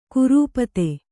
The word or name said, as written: ಕುರೂಪತೆ